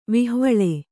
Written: ವಿಹ್ವಲ

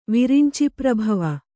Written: ವಿರಿಂಚಿಪ್ರಭವ

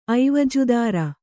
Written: ಐವಜುದಾರ

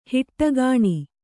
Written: ಹೊಡಕಣೆ

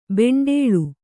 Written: ಬಂಡಲು

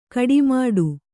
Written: ಗಟ್ಟಿಮುಟ್ಟು

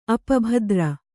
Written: ಅಪಭದ್ರ

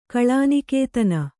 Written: ಕಲಾನಿಕೇತನ

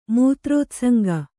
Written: ಮೂತ್ರೋತ್ಸಂಗ

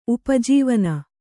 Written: ಉಪಜೀವನ

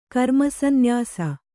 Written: ಕರ್ಮಸಂನ್ಯಾಸ

